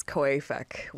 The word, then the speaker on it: COAFEC: We double-checked, and according to the African Development Bank, the correct way to pronounce an acronym is COAFEC.